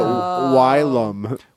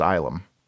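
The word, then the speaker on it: Ylum: Oh, I was calling it Wylam. – Well, it's funny because even on the first page, it tells you right at the bottom, it's pronounced Islam.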